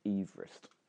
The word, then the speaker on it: Everest: Actually, it's meant to be pronounced Everest. – What? – The person for whom Everest was named used to pronounce his surname Everest.